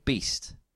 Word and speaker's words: B2ST: The next one, they're called Beast, and they're spelled B2ST.